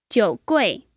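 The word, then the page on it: jiugui: jiu3gui4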